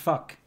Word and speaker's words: vak: You know, okay, let me, you know, in Afrikaans, subject is V-A-K, okay? – V-A-K, VAK. – No, but it's pronounced fuck.